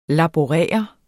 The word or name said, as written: laborere